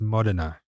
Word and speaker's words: Modena: Now, I don't want to get you in your head at all, but it's pronounced Modena. – Modena.